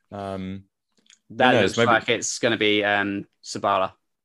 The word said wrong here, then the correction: Saliba: Did I say that right? Yeah. William Saliba. Saliba.